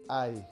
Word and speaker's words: I: Ay, se pronuncia. Como si nos hubiéramos pegado, ¿no? Ay, como si nos... Ay. Es el, el sonido de la I latina sola, así suena.